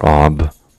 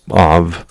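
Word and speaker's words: av (אב): In Hebrew, the word for father is av, or ab, but av, usually pronounced, it's a aleph bet, and that's, and I should have probably chose to capture the pointer, so you can do that.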